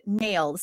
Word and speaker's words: NAILD: And if you want more information on the National Association of Lighting, Innovative Lighting Distributors, he says he pronounces it nailed. So it's NAILD .org.